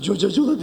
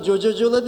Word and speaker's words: Coca-Cola: Affedersiniz, cocacola deyişinize güldüm. – Niye? – Çünkü ona cocacola değil, Coca-Cola derler. – O niye o? – Çünkü İngilizce'de C'ler K diye okunur.